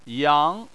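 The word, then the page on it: yang: tone 2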